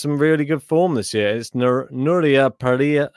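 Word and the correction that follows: Parrizas Díaz: uh, I'll say it right. Parrizas Diaz is her name.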